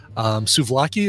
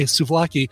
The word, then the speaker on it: Souvlaki: Suvlaki is spelled just like you think it would be, Souvlaki, Suvlaki. Check out the brand new EP and enjoy this track, Wake Me Up.